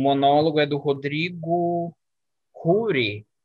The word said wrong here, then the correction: Roure: Hu, eu não sei pronunciar, mas. – Desculpa, boa. Oi? Não, boa, valeu pela. É isso mesmo, Rodrigo Roure, Rodrigo de Roure.